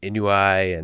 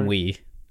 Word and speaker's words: ennui: like the constant sense of isolation and inui, and is it inui? Inui? How do you say that? Ennui. – Ennui. Wasn't even close.